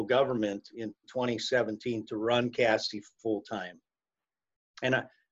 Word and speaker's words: CASE: And what's the role of do you pronounce it CASE for short? – CASE, we pronounce CASE.